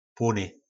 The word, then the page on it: plony: po nasimu